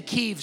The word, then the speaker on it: Kyiv: When I say Kyiv, some people call it Kiev. That's not how you pronounce it.